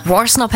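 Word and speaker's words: Worsnop: Stuff a Maximum Rock, Dark Thoughts, The Funeral Portrait featuring Danny Warsnop. – Die ist richtig ausgesprochen, Warsnop, ne? – Warsnop hätte ich jetzt auch gesagt, aber ich finde diesen Anfang so mega.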